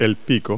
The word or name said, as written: beak